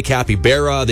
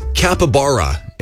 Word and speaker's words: capybara: My kids always yell at me because I say it wrong. I say capybara, say capybara. – Yeah. – It's capybara, is how it's said.